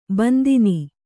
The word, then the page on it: ಬಂಧನಿ: bandhani